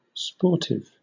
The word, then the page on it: sportive: British English